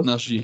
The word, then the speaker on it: Naji: von Jamal Naji spricht man ihn, glaube ich, aus. – Naji, genau, ja.